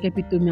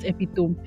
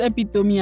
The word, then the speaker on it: epitome: First, let me go and check my dictionary because I cannot believe that your pronunciation is actually epitome and not epitome. You mean I've been jonesing myself all this while pronouncing it as epitome? I don't know how I never knew. It seems like one of those words that everybody's supposed to know. I have been here saying the wrong words. It's so unbelievable, but nothing actually spoiled. It is, well, so it's pronounced epitome, and it means the embodiment or encapsulation of a class of items.